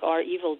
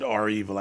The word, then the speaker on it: REvil: Well, and now I'll properly pronounce it R-Evil. I still like Revil, but that's just me.